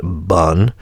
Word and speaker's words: Pun: It's spelled Pun, P-U-N, but it's pronounced Bun.